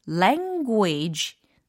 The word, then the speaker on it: language: 그리고 l n g 랭귀지가 아니라 language, language 이렇게 발음을 처리를 해주시면 훨씬 더 세련된 발음을 하실 수가 있어요.